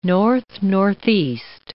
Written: north-northeast

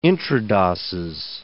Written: intradoses

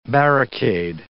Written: barricade

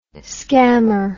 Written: skammer

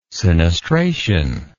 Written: sinistration

